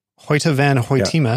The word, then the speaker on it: Hoyte van Hoytema: Well, and so here we are, the first time that he's working with cinematographer, I'm going to say this correctly, Hoyte van Hoytema.